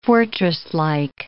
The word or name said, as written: fortresslike